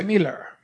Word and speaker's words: Millar: Mark Millar. – Y... – Es escocés. – No, Miller. Se pronuncia Miller. Igual que la Miller Lite.